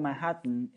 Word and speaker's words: Manhattan: Manhattan